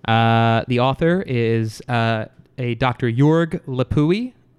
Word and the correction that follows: Leuppi: I hope I am pronouncing that correct. no, at all. – I think it. it's Leuppi.